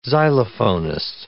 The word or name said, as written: xylophonist